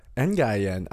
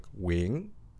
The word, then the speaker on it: Nguyen: What's an Nguy? It's just Nguyen, which I know, like, on paper doesn't make any sense. – Because the letters make it look longer than the actual pronunciation. – Yes, it does. But I think the problem is in Vietnamese, you would say, like, wing. And there's this, like, question and inflection.